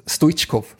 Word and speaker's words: Stoichkov: Men då försökte ju Bosse Hansson lite kuppa in uttalet Stoichkov. Och la till också, ja, han heter faktiskt så.